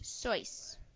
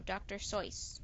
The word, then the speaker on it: Seuss: Dr. Seuss pronounced his name Soyce. So, Dr. Soyce.